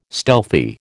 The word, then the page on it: stealthy: American English